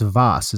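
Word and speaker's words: Waas: It's W-A-A-S, but it's pronounced Vaas.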